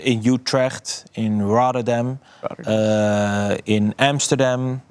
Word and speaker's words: Utrecht: Utrecht. Yeah, you say it correctly.